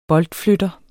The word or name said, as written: boldflytter